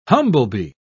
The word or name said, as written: humblebee